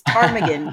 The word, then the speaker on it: Ptarmigan: Yeah, I had to look up a couple words like, I still want to say it wrong because I want to say it Parmesan, and it's not. It's the bird, and there's no bird named Parmesan, but it's Ptarmigan.